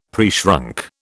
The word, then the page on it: preshrunk: British English